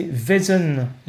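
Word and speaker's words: Vaesen: Alors génial, c'est Vesen qui veut dire essence, en suédois.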